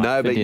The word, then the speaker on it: Nobu: Nobu, yes.